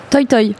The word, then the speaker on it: Toi Toi: Le titre de l'album, comment on, on doit le prononcer Toy Toy c'est une expression allemande qui veut dire bonne chance, euh, qui est très utilisée dans les arts de la scène.